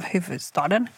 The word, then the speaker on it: huvudstaden: Det eh, som ägs av huvudstaden. Säger man huvud? Eller så... f kanske man inte uttalar.